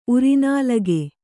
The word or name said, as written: ಉರಿನಾಲಿಗೆ